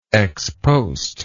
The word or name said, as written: ex post